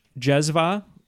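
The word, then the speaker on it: cezve: Question six: a Turkish jezva, Neapolitan flip, and Kyoto Dripper are all involved in the process of what? And if it helps, Jezva is spelled C-E-Z-V-E. I had to look up the pronunciation.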